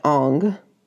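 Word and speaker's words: Ingres: It's an image of a piece by Ong. Thank you, my undergrad art professor, for correcting my pronunciation of his name.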